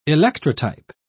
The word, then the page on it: electrotype: British English